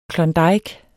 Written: klondike

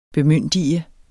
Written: bemyndige

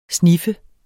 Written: snif

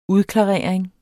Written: udklarering

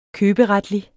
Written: køberetlig

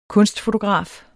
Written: kunstfotograf

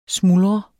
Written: smuldre

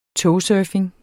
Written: togsurfing

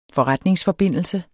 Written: FORRETNINGSFORBINDELSE

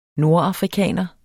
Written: nordafrikaner

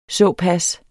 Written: såpas

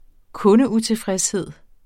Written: kundeutilfredshed

